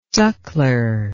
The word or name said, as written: suckler